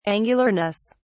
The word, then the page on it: angularness: British English